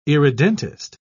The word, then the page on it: irredentist: British English